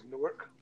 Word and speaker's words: Newark: I was in, I can never say this word, Newark Airport. Newark. Am I saying that shit right? – The correct way to say it is Newark.